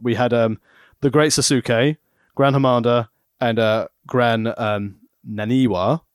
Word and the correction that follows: Sasuke: It's Sasuke, by the way, but it's okay, yeah. – Oh, Sasuke. – Yeah, just don't pronounce the U, it's fine.